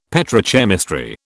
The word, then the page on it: petrochemistry: British English